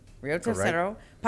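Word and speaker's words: Río Tercero: Your hometown is Rio Tercero? – Yes. – Am I saying that right? – That's correct. – Rio Tercero.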